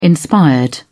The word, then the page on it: inspired: British English